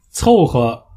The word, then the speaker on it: 凑合: 凑合，凑就是凑钱的凑，拼凑的凑。合就是合适的合，但是在这里要读作轻声呵呵，所以这个词的发音就是凑合凑合，而不是凑合凑合是不对的，凑合凑合才是对的。